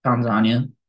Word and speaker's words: Tanzania: You got Tanzania, Tanzania, as alternatively pronounced.